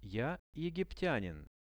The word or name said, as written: ja egiptianin